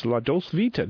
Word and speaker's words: La Dolce Vita: Today we're talking wines, La Dolce Vita. Did I say that right? – He said that very well.